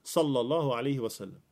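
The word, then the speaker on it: Sallallahu alaihi wasallam (صلى الله عليه وسلم): Vissa gör också fel när de uttalar det. Och det kan hända oss alla. Att man liksom nästan sluddrar när man säger det. Sallallahu alaihi wasallam.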